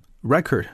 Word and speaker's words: record: Record 这个词，注意啊，它的这个发音啊，做名词的时候呢，就是重音落在第一个音节啊，record, record. So here's a phrase: keep records of something or keep a record.